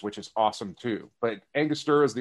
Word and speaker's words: Angostura: I can never pronounce Angostura. – No, well, and you still can't today. You still can't. It's Angostura bitters.